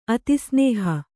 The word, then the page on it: ಅತಿಸ್ನೇಹ: atisnēha